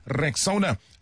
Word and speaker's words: Rexona: It's pronounced Rexona.